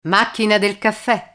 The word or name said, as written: macchina del caffe